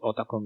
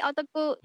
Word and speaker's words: ota: It's something like fan of something, like anime otaku. – Anime otaku, yes, yes, I've heard that, anime otaku. So technically, we are all, it's pronounced ota only, without the W? – Yep, ota.